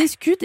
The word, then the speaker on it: escute: Alors déjà, première question, qu'est-ce qu'une escute et qu'est-ce qu'un bacove? Est-ce que je le prononce bien? – C'est bien ça, c'est bien ça.